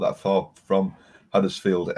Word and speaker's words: Huddersfield: There's got to be is that how you say it? Huddersfield? That's how they would say it.